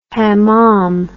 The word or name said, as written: hamam